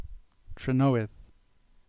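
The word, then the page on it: Trenoweth: Cornish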